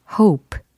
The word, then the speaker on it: hope: 발음 차이가 분명히 있죠. 예, 바라다, 희망은 hope. 요거고, 그 다음에 깡충 뛰어오르다 라고 할 때는 hop. hop. 예, 이렇게 좀 더 짧아지죠. 예, hop, hop. 모음이 좀 달라지죠.